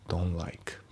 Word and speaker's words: don't: で、あと発音ですけど、 don't の t が抜けますね。 don't like、 don't like じゃなくて don't like。